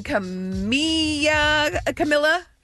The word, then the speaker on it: Camila Cabello: Julie Bowen, Jessica Biel, and Camila. Cabello.